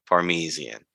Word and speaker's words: Parmesan: Like, everything was exactly the same except Parmesan. That is how you pronounce it.